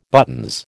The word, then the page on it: buttons: American English